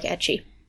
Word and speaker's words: ecchi: So, first off, I want to lay it out here that I like ecchi, which rhymes with sketchy because it is.